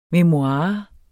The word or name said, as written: memoirer